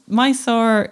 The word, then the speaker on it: Mysore: Yeah, so it's Mysore in English, or Mysuru, they would spell and pronounce it.